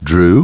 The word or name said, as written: Drew